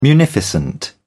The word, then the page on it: munificent: British English